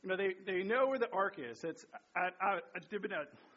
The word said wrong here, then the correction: Abinadab: I'm not saying his name right. Abinadab's house, right?